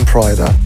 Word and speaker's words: Pryda: Piano, it was released first on Pryda. – That's how you say it, and we will play it next.